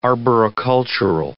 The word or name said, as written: arboricultural